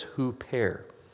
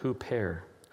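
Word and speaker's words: huper (ὑπέρ): But the Holy Spirit prays for us, and the Greek word there is huper. Can you say that? Huper. Huper. It's spelled in English U-P-E-R, but it's pronounced huper.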